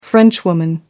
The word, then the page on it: frenchwoman: American English